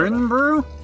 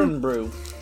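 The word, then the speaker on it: Irn-Bru: Urn Brew? – And it is, You a fan of the Urn Brew? – Urn Brew.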